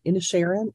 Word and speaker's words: Inisherin: Now, I'm not sure I said the name of that town right. Inisherin, is that how it's said, Mike? – Yes. Yeah.